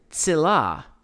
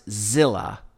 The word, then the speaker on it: Tzilah: Now, tsilah, in the King James Version, it's spelled, it looks like it should be pronounced zilla with a Z because it's spelled with a Z. In the Hebrew, it's in the romanization of it, it's spelled T-Z-I-L-A-H.